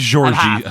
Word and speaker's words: Jorge: And it's around this point where we get introduced to, I believe I'm pronouncing it right, Georgi. – It could be George or Jorge, I don't know. – I believe it's Georgi, because I think I double-checked this. In Brazilian Portuguese, J-O-R-G-E is pronounced Georgi.